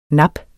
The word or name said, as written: nap